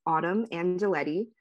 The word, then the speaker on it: Autumn Angelettie: My at name is just my first and last name, at Autumn Angelettie. Should I spell it? I guess it'll be written on the podcast. Okay, I'll spell it. So at AUTUMN Angelettie, AngelettiE, at Autumn Angelettie.